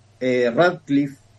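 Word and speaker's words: Ratcliffe: Lo más firme hasta ahora es que el titular de la CIA, que sería Radcliffe, si es que lo estoy pronunciando bien. – Perfectamente, como si fueses un nativo.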